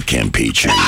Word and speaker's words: Campeche: Will it make it to the Bay of Campeche? – No, you got to say it right. It's the Bay of Campeche.